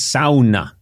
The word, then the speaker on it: sauna: And thank you for saying it correctly, because here in the United States, people say, do you want to, I went to a sauna. – Sauna. – But I learned in Finland, it's sauna.